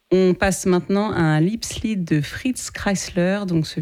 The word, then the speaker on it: Liebeslied: Ah, j'avais mal prononcé. Alors, Liebeslied.